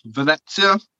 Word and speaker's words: Venezia: Yeah, and it's Roma against Venezia. Excuse me if I'm pronouncing that wrong. – No, both of them are fine. As long as you don't change the pronunciation throughout it, that's my thing.